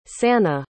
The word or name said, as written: sannie